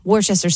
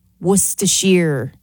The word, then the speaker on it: Worcestershire: And also, Worcester sauce. Worcestershire is how you pronounce it.